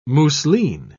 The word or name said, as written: mousseline